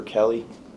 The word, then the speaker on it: Kelley: Officer Christopher Kelly. Last name is spelled K-E-L-L-E-Y, and I'm a patrolman with the Ansonia Police Department.